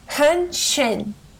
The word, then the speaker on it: Hähnchen: Hähnchen. – Hähnchen. – Hähnchen. – Hähnchen. – There you go. That's how you say chicken in German. – Like henchman. – Basically, like henchin.